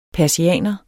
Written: persianer

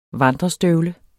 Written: vandrestøvle

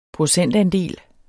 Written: procentandel

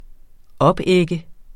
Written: opægge

